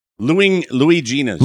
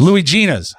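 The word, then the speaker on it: Luigina's: So it's, what's it called? Fuanguilas? – Louis Gina's. – Louis Gina's. How do you spell it? – Luigina apostrophe S.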